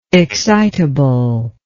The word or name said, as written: excitably